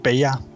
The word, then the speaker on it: PEA: En het wordt eigenlijk gewoon in Nederlands meestal uitgesproken als PEA. – Ja, dus, PEA Pure. – Eigenlijk zijn drie letters, hè, P-E-A. Dus, en dat is de afkorting, maar lekker gewoon PEA. Ja, makkelijker kan het eigenlijk niet.